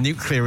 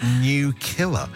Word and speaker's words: nuclear: and nuclear is not new killer, says Joe.